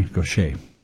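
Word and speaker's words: Gauthier: Mary Gaucher. And Gaucher is spelled G-A-U-T-H-E. Excuse me, I-E-R. She's French. So it has a different sound.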